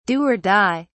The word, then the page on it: do or die: British English